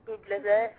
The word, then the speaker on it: Bublé: Play, or I don't know if I'm pronouncing his last name right, but Michael Bublé. Bublé. – Oh, no, that's right.